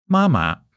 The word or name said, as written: ma1 ma5